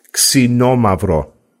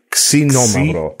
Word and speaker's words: Xinomavro: The variety of this region is a variety, ready guys, Xinomavro. – I always thought it was Z. – Xinomavro. Yeah, it's spelled X-I-N-O, and that etymology means sour or acidic.